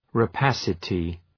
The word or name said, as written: rapacity